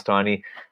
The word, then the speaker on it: Taney: And of course, along the way, Chief Justice Taney, who wrote the pronounced Taney,